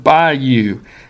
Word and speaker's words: Bayou: By the way, you'll discover from the locals that when you pronounce the name of that place, don't try to sound out two strong syllables in that word Bayou. Instead, run it together so it almost sounds like you're walking out the door telling someone bye.